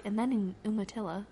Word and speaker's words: Umatilla: I found out after our trip was over that it's pronounced Umatilla, not Umatilla.